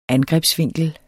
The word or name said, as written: angrebsvinkel